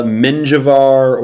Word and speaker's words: Menjivar: They have one fella, Menjivar. – How's it spelled? – A soft yay. M-E-N-J-I-V-A-R. – Soft J.